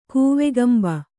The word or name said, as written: ಕೂವಕಂಬ